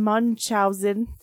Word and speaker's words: Munchausen: The Adventures of Baron Munchausen.